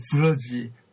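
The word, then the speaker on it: Brugge: 여러분 꼭 한번 이 기회가 되시면은 벨지움의 이 브러지 그 브러흐라고도 발음하는데요.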